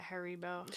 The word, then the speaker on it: Haribo: Candy corn, just can't we just can you just make you know how like Haribo is that how you say it? Haribo, sure.